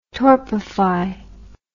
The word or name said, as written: torpify